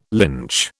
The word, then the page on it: lynch: British English